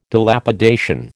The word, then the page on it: Dilapidation: American English